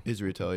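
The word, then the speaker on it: Israetel: Is that actually how you say his name, or Israetil? Yeah. – All right. Just making sure. – Positive. 100%.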